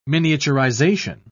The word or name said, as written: miniaturization